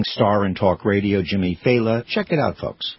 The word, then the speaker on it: Failla: His name is pronounced Faila. Faila. It's spelled F-A-I-L-L-A. So people go Failia, Failia. You know, but it's Faila.